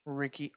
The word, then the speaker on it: Rickey: Ricky, R-I-C-K-E-Y.